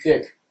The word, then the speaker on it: Dyk: this guy's last name is spelled D-Y-K. It's pronounced Dick. – Dick.